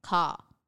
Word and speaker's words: Kaa: Well, if this one says here, Kaa, the snake is meant to be Kaa, K-A-R, but to me it's still Kaa, not Kaa. – So can you repeat that again? – So so the Kaa is spelt K-A-A. – Yes. – But it says the pronunciation is K-A-R. – Yes. – Car. But then ka. Ka? I don't know. – Yeah, okay. There's a very slight distinction.